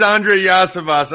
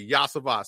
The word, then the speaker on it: Andre Yosivas: Listen, I heard like pronounced like 4 different times when I was. I'm pretty sure it's Andre Yosivas. I think the third time's a charm there. – Hey, you got it right, Yosivas.